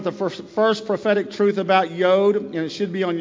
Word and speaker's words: Yod: And let's talk about Yod. Everybody say Yod. Some people pronounce it Yud, but I have a habit of saying Yod.